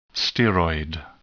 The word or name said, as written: steroid